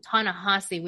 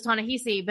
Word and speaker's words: Ta-Nehisi: By the way, it is Ta-Nehisi. We've been saying it wrong time. No, I was too. I always thought it was Ta-Nehisi, but I've never heard it out loud. Apparently, I've only read it.